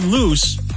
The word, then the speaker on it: Luce: The new Ferrari Luce, it's spelled L-U-C-E, but without an accent on the E, so it's Luce and not Luce.